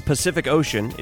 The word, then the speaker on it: Pacific Ocean: Every C, the letter C, in Pacific Ocean is pronounced differently. The first C is an S sound. The second C is a hard C. I don't know. Is that what you call it? Like a K sound. And then ocean. Osh. That's like an SH. Ocean. Interesting. Pacific Ocean. Every C is pronounced differently.